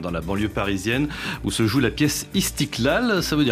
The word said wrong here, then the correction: Istiklal: Mais d'abord, c'est Istiklal, dans le bon accent arabe.